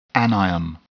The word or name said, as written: anion